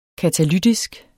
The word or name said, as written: katalytisk